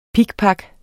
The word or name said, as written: pikpak